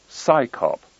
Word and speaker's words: CSICOP: Its initials, CSICOP, are pronounced as the acronym PSICOP, as if it's an organization of scientists performing a police function.